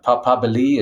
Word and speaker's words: papabili: Yes, it's papabile is the singular. Papabili is plural. That's the Italian.